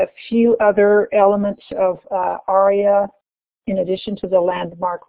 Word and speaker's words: ARIA: We'll talk about introductory ideas related to ARIA, A-R-I-A, which I will be pronouncing ARIA.